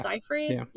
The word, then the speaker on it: Seyfried: Is it Seyfried? – Yeah.